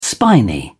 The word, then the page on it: spiny: British English